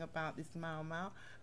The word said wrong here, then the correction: moi moi: It's called moi moi and it's spelled MOIMOI.